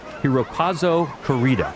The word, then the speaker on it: Hirokazu Koreeda: This is the latest from writer-director Hirokazu Koreeda, mm-hmm.